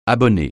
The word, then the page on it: abonné: francais